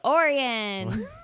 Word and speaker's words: Orion: Welcome back to the Loyal Littles podcast, and thank you, for coming on from Lake Orion.